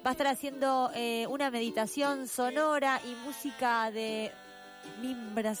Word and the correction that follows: marimba: ¿se dice? ¿Cómo se dice? Ahí le quedó. Marimba. – ¿Sabes? Ah, ¿de marimba? – Así está bien dicho, marimba.